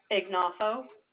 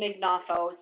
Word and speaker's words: Ignoffo: Liesl Ignoffo. – Wait, how do you spell that? L-E? – first name is Liesl, L-I-E-S-L. – Last name? – Mm-hmm. Last name Ignoffo. Starts with an I, G-N-O-F-F-O. – F-F-O.